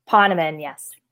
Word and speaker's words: Ponaman: We were just going over that pronunciation. Ponaman, correct? – Ponaman, yes.